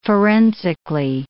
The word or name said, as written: forensically